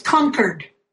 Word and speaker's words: Concord: so I know that it's really pronounced Concord, New Hampshire.